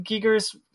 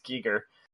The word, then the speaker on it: Giger: Giger's, I'm pronouncing it wrong, it's Giger.